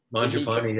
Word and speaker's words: Mangiapane: You were big on Mangiapane, is that how you say it? – Mangiapane, yeah.